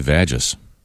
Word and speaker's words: vagus: V-A-G-U-S. – U-S. – Say vagus. – I say it's vagus. – Could we get a doctor to call us and tell us what it is? – V-A-G-U-S.